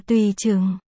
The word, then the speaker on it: Tùy Trừng: Cái ít đó, đọc là Tùy Trừng. Họ Tùy, tùy tự một tiếng. Họ Trừng, Trừng trong Trừng Triệt, là tài khoản của em gái tôi. Trừng Triệt, nước trong.